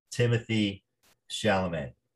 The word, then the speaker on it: Timothée Chalamet: Timothée Chalamet, if I'm saying that correctly.